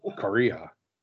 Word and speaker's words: Kariya: Yeah, because if you hear Paul Kariya, I mean, the worst case scenario, you spell it like the country. Like, yeah, K-O-R-E-A. Like Korea. Yeah. – Kariya. But Kariya.